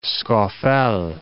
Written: Scafell Pike

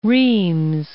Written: Rheims

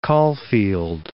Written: Caulfield

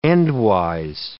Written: endwise

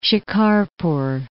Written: Shikarpur

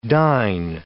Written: dyne

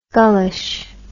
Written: gullish

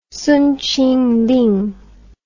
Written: Song Qingling

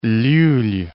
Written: Llull

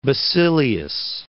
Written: Basilius

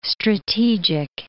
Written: strategic

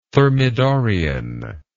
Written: thermidorian

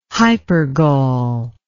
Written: hypergol

